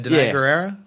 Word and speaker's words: Danai Gurira: Danai Gurira. How do you say her fucking name? – I think it's like what you said. Danai Gurira? – Yeah. I did all right.